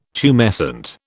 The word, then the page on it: tumescent: British English